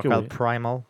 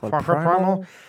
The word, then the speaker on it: Far Cry Primal: Donc, euh, Far Cry Primal, est-ce que je le dis bien? Oui, je pense Far Cry Primal. Primal. Primal. – Far Cry Primal.